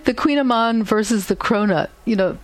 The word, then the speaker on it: kouign-amann: But then there's a lot of totally new things, like on the cover, the pastry that's called the kouign-amann. Mm-hmm. I think of it as the Queen of Pastry because if you look at the spelling, K O U I G N, you'd never pronounce it that way.